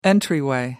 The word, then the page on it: entryway: American English